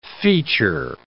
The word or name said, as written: feature